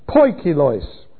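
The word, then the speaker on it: poikilos (ποικίλος): P-O-I-K, poik, I-L-O-I-S, plural, poikilos. It means dappled, marbled, different strands, varied, all kinds, diverse. And that's how it's usually translated in the New Testament. Poikilos, various kinds.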